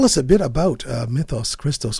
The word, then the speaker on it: Mythos Christos: You know, I say Mythos Christos. Um, I guess if we were going to go Greek, we would say mythos, mythos Christos.